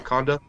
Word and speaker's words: Wakanda: Wakanda? – Yeah.